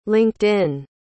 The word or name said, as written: linkedin